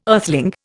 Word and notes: earthling: Indian

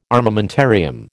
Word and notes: armamentarium: American English

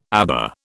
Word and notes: aba: British English

